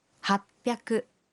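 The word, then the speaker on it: happyaku: Y 800 se dice hapyaku.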